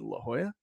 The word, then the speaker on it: La Jolla: I'm terrible with if I should pronounce J's as H's or not. As evident as the time that I told my friends I was staying in La Jolla, California, and they're like, "You mean La Jolla?"